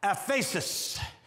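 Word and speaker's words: aphasis (ἄφεσις): And it comes from a Greek word that is pronounced aphasis.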